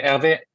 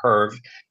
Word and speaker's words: Hervé: Hervé. Yeah, Hervé with an accent, because if you remove the accent, Herve, it sounds like a sexually transmitted disease.